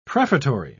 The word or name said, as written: prefatory